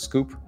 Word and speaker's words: SKWP: It's SKWP, pronounced Scoop, SKWP.